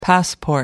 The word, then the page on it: passport: American English